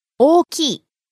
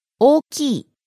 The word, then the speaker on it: 大きい: Now, I don't know if you can hear it, so can you break it down? – O-O-K-I. 大きい. – So there's an extra E syllable on the end.